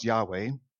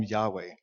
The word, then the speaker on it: Yahweh: And we said, we learned earlier that his name, his personal name, we think it's pronounced Yahweh.